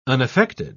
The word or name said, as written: unaffected